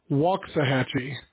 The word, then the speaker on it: Waxahachie: Well, contrary to the way that it's spelled, the proper pronunciation is Waxahachie. Texas has got a lot of funny towns like that. They're not pronounced the way that they're spelled.